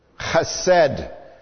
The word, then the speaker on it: Chesed: Chesed. Listen to that Hebrew pronunciation. That's exactly right.